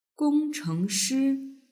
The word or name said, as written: gongchengshi